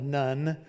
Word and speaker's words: Nun: And Joshua was the son of Nun, N-U-N. That's the proper way to say his name, not the son of Nun, N-U-N, but Nun. It's a Hebrew letter, actually.